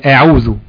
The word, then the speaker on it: أعوذ: أو أعوذ، هي أعوذ. تمام. تفخيم الهمزة في بداية الكلام.